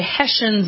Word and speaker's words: Hessians: All right, and just to continue on, by the way, Hessians is the proper pronunciation of those German mercenaries.